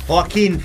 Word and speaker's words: Joaquin: Joaquin Phoenix. – Joaquin, ma perché Joaquin? – Perché si pronuncia così.